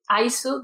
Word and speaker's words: Aisu: chirin, chirin, Aisu, de ice cream. – Ah, Aisu.